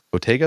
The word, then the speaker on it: bottega: Bottega, is that how you pronounce it? – Bottega, yes. Yeah.